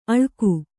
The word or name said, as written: ಅಳ್ಗು